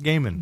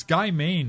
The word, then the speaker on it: Gaiman: No one wants to admit his name is pronounced Gaiman. – There's an I in it. – So? – There's two I's in it. It's Guy Maine, the way he's written it.